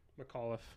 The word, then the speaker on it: McAuliffe: How do you say that? – Let me say it. McAuliffe. – McAuliffe? – Yeah.